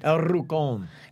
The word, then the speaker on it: El Rucon: How do you say it again? – El Rucon. – Spell it. – It is E-L-R-U-C-O-N.